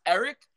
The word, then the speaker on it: Arik: It's pronounced Eric, but it's spelled A-R-I-K, which is kind of crazy. You know, like Eric, you know, but it's Eric Armstead.